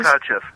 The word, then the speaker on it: Kotcheff: Kotcheff, right.